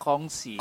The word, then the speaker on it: Kongsi: Gongsi Facoy semuanya. eh benar gak? – Gongsi, gongsi Facoy ya. – Bukan gong, bacanya oh. kongsi. Kongsi, oh maaf, ah, maaf, maaf.